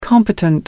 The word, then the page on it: competent: American English